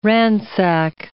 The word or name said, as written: ransack